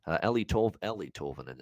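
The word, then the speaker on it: Eeli Tolvanen: It looks like Eli Tolvanen, by the way. Ellie Tolvanen is actually how you say it.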